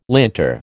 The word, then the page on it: linter: American English